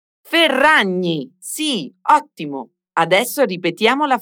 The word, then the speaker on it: Ferragni: Pronuncia bene questa doppia R e la gn Ferragni. – Ferragni.